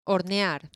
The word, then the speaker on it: hornear: Hornear.